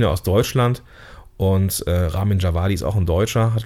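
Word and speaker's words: Ramin Djawadi: Ramin Javadi, genau.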